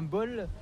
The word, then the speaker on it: bowl: Se prononce comme bowl, mais c'est bowl. – Bowl, mais c'est pas comme un bol. – C'est B-O-W-L. – Un bol comme dans lequel on met le café, par exemple.